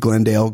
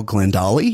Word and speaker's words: Glendale: Where she would also mispronounce, where she would call the city of Glendale Glendolly.